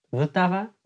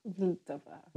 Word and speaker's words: Vltava: Dus de Tsjechische naam is Vltava. Vltava. – Vltava. – Ik ga het even zo uitspreken. Het wordt ook de Moldau genoemd. Dat is de Duitse naam.